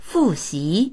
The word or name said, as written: fu4xi2